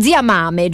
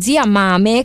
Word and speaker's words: Mame: Io l'ho sentito Corretto. – Pronunciare in 20 modi diversi, Zia Mame, Zia Mame, però noi lo diciamo Zia Mame, così è anche un po' più facile da andare a ritrovare se per caso qualcuno se lo volesse segnare. È proprio scritto così.